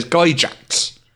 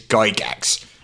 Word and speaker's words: Gygax: and he very, very specifically and clearly said it's Gygax. – That is how his family pronounces that name.